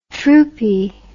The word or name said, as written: troopie